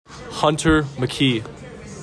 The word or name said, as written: HUNTER MCKEE